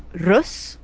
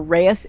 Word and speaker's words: Reus: Okay, so the next game that we are going to be playing is Rus, or Rus if you don't want to roll your R's, or Reyes if you want to just pronounce it wrong. which is it's the Dutch word for giant. It's spelled R-E-U-S.